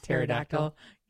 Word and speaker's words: pterodactyl: Because the P is silent. – pterodactyl. – Yep. T-T-E-R-O-D-A-C-T-Y-L.